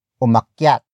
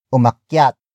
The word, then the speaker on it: umakyat: which is umakyat. Umakyat. Umakyat. Umakyat is spelled as U-M-A-K-Y-A-T.